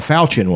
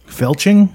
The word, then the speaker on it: falchion: It wasn't a falchion, was it? – Felching? – Falchion.